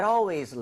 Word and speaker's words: always: That always leaves the TV on. 정답. 맞습니다. – Yes. – A-L-W-A-Y-S. – Mm-hmm. – 발음할 때 아시죠? Always가 아니라 A-L은 항상 all에 가깝게 이 모양 벌리셔야 됩니다. Yes.